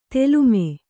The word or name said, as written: ತಾಲೀಮ